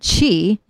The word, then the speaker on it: qi: So the word qi is just the word energy in Chinese.